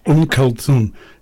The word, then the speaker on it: Kulthum: Kulthum. Yeah, very good. – Kulthum.